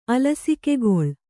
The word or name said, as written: ಅಲಸಿಕೆಗೊಳ್